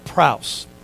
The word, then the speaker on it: praus: The Greek word that is used in the Sermon on the Mount in verse 5, blessed are the meek, is a little Greek word that's pronounced praus, like P-R-A-U-S, praus.